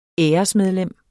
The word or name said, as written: æresmedlem